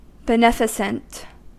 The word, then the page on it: beneficent: American English; /bəˈnɛf.ə.sənt/